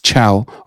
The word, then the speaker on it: Ciao: So in case you don't know how you spell it, it's Chow. All right. C-I-A-O.